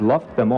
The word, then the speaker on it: loved them: You basically kind of skip the d, and you go directly to them.